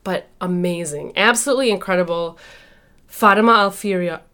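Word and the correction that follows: Fatima al-Fihri: Oh my gosh, I said it wrong. The last one. I did it well the whole time. Fatima Al-Fahiri.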